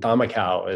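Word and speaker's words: camakau: However, none of us had ever paddled a traditional Fijian thamakau, is how that's pronounced, which is that they made these boats for the race in the traditional design.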